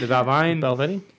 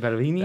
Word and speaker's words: Balvenie: The Valvine. – Balvenie? – The Balvenie. – Balvenie.